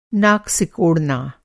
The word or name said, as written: सिकोड़ना